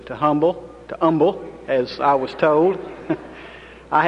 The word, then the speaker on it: Humble: It's pronounced Humble. The H is silent.